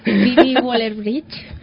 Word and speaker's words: Phoebe Waller-Bridge: Phoebe Waller-Bridge, ¿lo he pronunciado bien o no? – Sí.